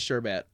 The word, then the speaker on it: sherbet: Oh, it is sherbet. – I told you, motherfucker. – Rainbow sherbet. That's so dumb. Fucking stupid. Okay, hold on. Let me listen to her say it. Sherbet. Ugh. I've been pronouncing it wrong my whole life.